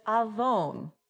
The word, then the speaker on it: avon: The Hebrew word for iniquity is avon. Kind of rhymes with iPhone.